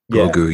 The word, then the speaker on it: Grogu: It's got like a vowel rhyme with Yoda. Grogu, yeah.